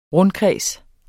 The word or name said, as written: rundkreds